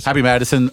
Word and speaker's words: Happy Madison: Happy Madison Productions, you know, Adam Sandler's studio.